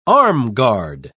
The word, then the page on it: armguard: British English